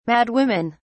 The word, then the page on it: madwomen: British English